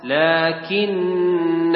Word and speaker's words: لكن: لكن هو الله ربي. هذه إذا واصلنا نحذف الألف، نقول لكن هو الله ربي، وإذا وقفنا نقرأ الألف لكن. هذا عند الوقف ننطق بألف، وعند الوصل نحذف هذه الألف، على رأسها صفر مستطيل.